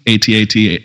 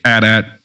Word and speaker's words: AT-AT: And he said that one, AT-AT, AT-AT. It's both right.